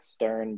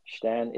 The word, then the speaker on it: Stern: I had my German friend try to explain that you don't pronounce the R. It looks like Stern, but Stern.